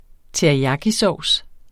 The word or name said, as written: teriyakisovs